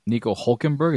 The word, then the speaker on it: Nico Hülkenberg: I guess so Nico Hülkenberg, is that how you say it? – Yeah.